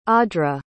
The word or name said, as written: audre